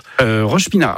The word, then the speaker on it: Roche-Pinard: Et à Roche-Pinard. Alors, c'est ça, c'est bien comme ça qu'on prononce. – Roche-Pinard.